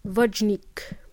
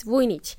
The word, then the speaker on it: Vujnic: So when the native speakers try to pronounce my name, they pronounce it as Vojnik. And in Spanish, my Spanish friends, they pronounce my name as Buchnik. So it's none of those. It's Vujnic.